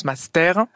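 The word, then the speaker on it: mastère: Alors, j'ai vu plusieurs manières, mais la plus répandue, c'est donc M-A-S-T, accent grave, R-E. Donc, ça se prononce master. Mais justement, à l'oral, master, on fait très peu la différence.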